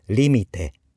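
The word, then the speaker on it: limite: はい、続いてリミテ、これアクセント気をつけましょう。リミテ、頭にアクセントがあります。限界とか境界線の意味です。リミットですね。リミテ、はい、絵で終わっています。これは男性名詞です。リミテ、だから複数形はリミティ、リミテ、リミティ、限界です。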